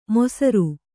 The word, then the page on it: ಮೊಸರು: mosaru